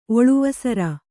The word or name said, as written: ಒಳುವಸರ